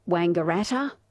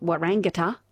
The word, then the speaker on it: Wangaratta: that's not how you say Wangaratta, it's not Warangata.